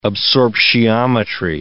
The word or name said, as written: absorptiometry